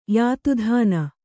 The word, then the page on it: ಯಾತುಧಾನ: kannada